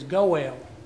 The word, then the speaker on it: goel: A kinsman redeemer in Hebrew is goel. That's how it's pronounced.